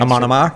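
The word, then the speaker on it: Amon Amarth: Amon Amar? – Thank you.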